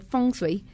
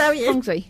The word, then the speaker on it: feng shui: Entonces, ahí de ahí el nombre tan poético de feng shui, eh, que se pronuncia feng shui. Eh, aquí en México dicen feng shui porque pues así se lee y ya me cansé de debatir. Díganle como quieran, pero es feng shui.